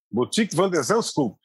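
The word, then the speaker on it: Botic van de Zandschulp: Boutique van der Zandskulp. Essa é a pronúncia do nome dele.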